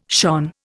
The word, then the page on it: shone: Indian